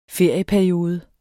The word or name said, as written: ferieperiode